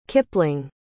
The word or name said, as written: kipling